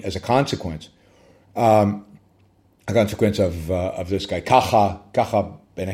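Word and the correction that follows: Kakha: Yeah, Saba says, his name is Kacha. Yes, Kacha, but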